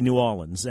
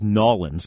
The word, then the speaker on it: New Orleans: I pronounce that R. New Orleans. – I say New Orleans. N-O-O-A-W-L-I-N-S. – Okay. – One thing I can't stand as a local is Nawlins, because that's like,